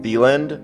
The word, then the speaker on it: Theland: I definitely like that the name of the place is, you pronounce it Theland, but it's also just the land, like you also said.